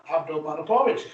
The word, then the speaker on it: Abdulmanapovich: Abdulmanapovich.